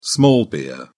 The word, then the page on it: small beer: British English